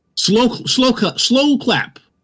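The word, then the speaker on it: Sloclap: Slow clap. That's how you pronounce them.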